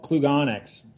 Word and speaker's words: Klugonyx: Yeah, it's called Klugonyx. – Klugonyx.